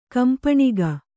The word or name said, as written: ಕಂಪಣಿಗ